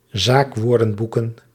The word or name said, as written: zaakwoordenboeken